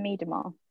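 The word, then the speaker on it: Miedema: So how do you pronounce the forwards' name? Is it Miedemeyer? – I pronounce it Vivian Miedemeyer.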